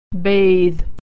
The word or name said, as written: bathe